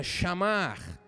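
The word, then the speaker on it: chamar (שמר): A palavra em hebraico se pronuncia chamar, que traduzindo também tem a expressão de vigiar, guardar, proteger, tomar sob custódia ou cuidar.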